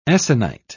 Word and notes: essonite: British English

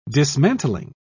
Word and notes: dismantling: British English

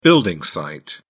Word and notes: building site: British English

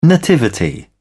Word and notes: nativity: British English

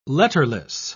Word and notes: letterless: British English

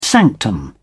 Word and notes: sanctum: British English

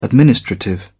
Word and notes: administrative: British English

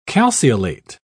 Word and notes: calceolate: British English

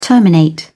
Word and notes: terminate: British English

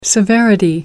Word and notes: severity: American English

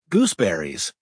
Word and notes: gooseberries: American English

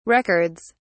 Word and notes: records: British English

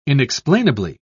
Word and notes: inexplainably: British English

